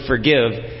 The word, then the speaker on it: forgive: Kasi forgiveness, siyempre yung word forgive, let's do it in two ways. The first way is merong emphasis or maglalagay tayo ng bigat doon sa second part of the word.